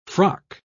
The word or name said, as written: frock